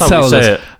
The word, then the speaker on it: Enceladus: How do you pronounce it? Enceladus. – That's Enceladus.